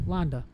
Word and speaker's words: Landa: Landa.